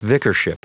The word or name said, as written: vicarship